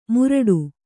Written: ಮುರಡು

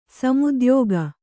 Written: ಸಮುದ್ಯೋಗ

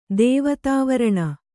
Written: ದೇವತಾವರಣ

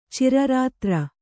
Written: ಚಿರರಾತ್ರ

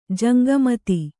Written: ಜಂಗಮತಿ

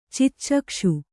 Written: ಚಿಚ್ಚಕ್ಷು

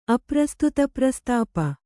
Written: ಅಪ್ರಸ್ತುತಪ್ರಸ್ತಾಪ

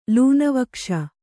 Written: ಲೂನವಕ್ಷ

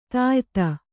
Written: ದ್ಯುತಿ

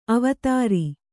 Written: ಅವಧಾರು

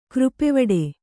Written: ಕೃಪೆವಡೆ

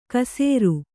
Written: ಕಸೇರು

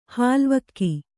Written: ಹಾಲ್ವಕ್ಕಿ

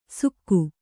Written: ಸುಕ್ಕು

